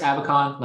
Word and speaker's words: Abacon: You can find me on LinkedIn under Chris Abacon. Last name is spelled A. Bacon.